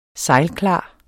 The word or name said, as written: sejlklar